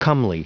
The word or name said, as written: comely